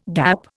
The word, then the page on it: gap: Indian